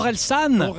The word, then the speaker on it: Orelsan: I'm pronouncing it wrong. I think it's Horizon. – Ah, Orelsan. – Orelsan. – Horizon. – Yeah. Horizon means horizon, so it was a bit weird.